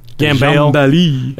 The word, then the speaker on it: Gambale: Was it Gambale, you think? – I don't know. – G-A-M-B-A-L-E? – Gambale. – It's Gambale. He's Australian. It was sort of Frank Gambale kind of thing, or Gambale kind of thing.